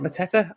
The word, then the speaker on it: Mateta: Is it Mateta? I always get his name. – Yeah. – I get his name wrong every time, but I think I always think he causes issues.